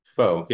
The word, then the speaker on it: faux: Faux? – Faux, yeah.